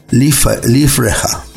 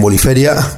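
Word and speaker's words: Liferea: El autor, Lars Linder, que es alemán, pronuncia nombre como Liefreja. Pero bueno, yo lo llamo Boniferia, cada uno lo pronuncia como quiere.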